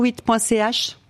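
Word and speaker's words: shewit.ch: Vous pouvez tout retrouver sur chevitte.ch. Vous très bien. – Tout. – Et ça s'écrit, ce qui n'est pas forcément évident, S-H-E-W-I-T.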